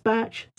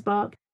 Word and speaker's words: Bach: Another thing that I like to use, something called bark flower remedies, B-A-C-H. Sometimes it's pronounced batch, sometimes bark. B-A-C-H, flower remedies.